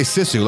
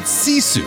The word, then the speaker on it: Sisu: You don't say Sisu like we were all saying for a while. Okay, you pronounce it Sisu from the director of Rare Export.